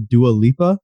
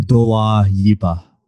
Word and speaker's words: Dua Lipa: For me personally, I recently got into Dua Lipa. I hope I'm pronouncing that right. And there's not like... her fans actually, that are listening to this, like it's bullshit. Actually Dua Lipa. – Dua.